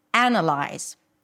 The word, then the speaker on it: analyze: Listen to the word, analyze, analysis, analyze. What's the difference? So the difference, again, is in the primary stress, right? Here, the primary stress falls on analyze, the first syllable, analyze.